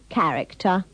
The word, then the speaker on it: character: In these sentences, remember to pronounce the CH in character and characteristic with the sound of the C in cow. – The man who got on the bus had a really nasty character.